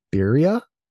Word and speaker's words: birria: They're inspired by, and please correct me if I say this wrong, but birria? – Yeah, you have to roll the R's a little more, but I can hardly do it myself. Yeah, so close enough.